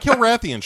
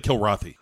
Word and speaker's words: Kilrathi: Not Kilrathians, just the Kilrathi.